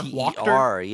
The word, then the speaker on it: Wachter: W-A-C-H-T-E-R. – Wachter? Yeah. Wachter. The T's in a new and exciting place. – The T is in a new and exciting place. Watu the Wachter.